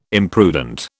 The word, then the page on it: imprudent: British English